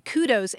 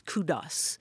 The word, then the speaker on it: kudos: But actually, the original Greek word for glory is kudos. So it has that S sound at the end of it. But, you know, I mean, I've certainly heard people say, I'm going to give you a kudo for that wonderful dish that you whipped up.